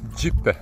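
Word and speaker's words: Gjipe: Cijelim krajem dominira zaliv Đipe sa plažom i kanjonom koji je dug oko 4 km. E, sada smo se uputili ka plaži Džipe. Aleks, da li sam pravilno da, da, izgovorio?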